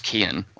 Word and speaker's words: Keoghan: Well, I like the setup mainly because of the casting of Barry Keoghan. I'm not sure if I'm saying his name correctly. – I think you just ignore the G. I think it's just Keoghan. Like, it's just Keoghan? Right.